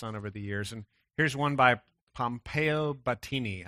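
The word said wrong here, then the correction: Batoni: I hope I said that right. If you're Italian, tell me afterwards how to say that properly. But no, it's Battoni. Pompeo Battoni.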